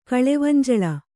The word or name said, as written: ಕಳೆವಂಜಳ